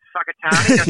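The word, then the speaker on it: Whakatāne: It's Whakatane. – Yep. – And it's actually spelt with a WH. It's very weird.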